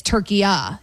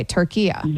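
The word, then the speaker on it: Türkiye: Okay, so it's not what we would call, you and I are making it a hard A, Turkey A, and it's Turkiya, he's saying. So it's a soft A.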